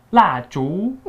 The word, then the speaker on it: 蜡烛: The Chinese for candle is 蜡烛.